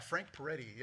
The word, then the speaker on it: Frank Peretti: Yeah, Frank Peretti.